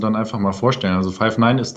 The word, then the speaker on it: Five9: Oder wie spricht man das Ganze aus? – Ja, ist richtig. Five9 wird es ausgesprochen.